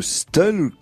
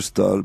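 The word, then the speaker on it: Staël: Et, alors, il y, a, là toute une série de règles qui font que, par exemple, euh, Madame de Stel, que je dis souvent S-T-A-E, tréma L, en fait, il faut dire Madame de Stal.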